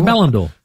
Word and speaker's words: Ballon d'Or: Another one, Ballon d'Or. Did I say that right? – The Ballon d'Or.